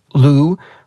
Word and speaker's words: Lu: So in both Mandarin and Cantonese, Lu,